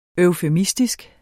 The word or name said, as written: EUFEMISTISKE